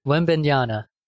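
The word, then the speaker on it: Wembanyama: Webinyana. – Webinyana. – Webinyana, yep.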